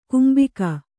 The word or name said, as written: ಕಂಬಿಗ